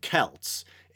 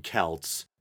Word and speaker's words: Celts: And I believe he actually pronounced Celts. And I think that might be the less common pronunciation, but I'm not sure it's necessarily entirely wrong. It usually is pronounced Celts or Celtic.